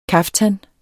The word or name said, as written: KAFTANERNES